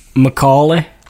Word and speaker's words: Macaulay: Macaulay Culkin. I can't say his name. I've never been able to say his name. – Macaulay.